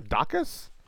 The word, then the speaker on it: Daukaus: Is that how you say your name? Dacus? – Yeah.